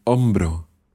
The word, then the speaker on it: hombro: We're going to learn one more word, and that is el hombro. – El hombro. – El hombro. Now notice that hombro starts with an H. It's a silent H. El hombro.